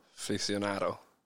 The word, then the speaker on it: aficionado: Barefoot Aficionado? – Aficionado. – Aficionado? Am I saying it right? Aficionado? – Aficionado. – Okay, so I've been pronouncing it wrong.